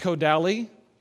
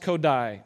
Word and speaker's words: Kodály: Zoltan Kodály, it looks like Kodály, but it's pronounced Kodály, faced a similar situation in Hungary in the early 1900s.